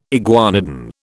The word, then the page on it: iguanodon: British English